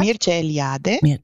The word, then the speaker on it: Mircea Eliade: Spreche ich ihn eigentlich richtig so aus? Mircea Eliade? – Ja, Mircea Eliade. – Eliade, okay. Siehst du, das E spricht man, Mircea Eliade, aus den magischen Geschichten, die Edith Silbermann übersetzt hat.